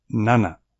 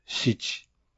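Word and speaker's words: なな: El 4 figura como yon, no como shi, y el 7 como nana, no como shichi.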